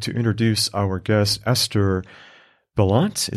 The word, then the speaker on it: Balint: Balint, more of the emphasis. Balint. – Yeah. – Okay. All right. I wanted to make sure I got that correct because I've heard it pronounced different ways.